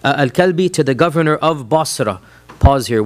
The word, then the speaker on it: Basra: Any reference of Basra, which is how it's pronounced, in the Sirah literature is to a small town south of Damascus.